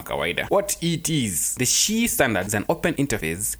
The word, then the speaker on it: Qi: Now, I'm talking about the SHI standard, spelling QI, but pronunciation is SHI, because it focuses on power.